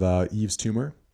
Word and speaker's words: Yves Tumor: In the new artist segment, I have Yves Tumor, who is an experimental artist. It's pronounced, or it's spelled Y-V-E-S and then T-U-M-O-R.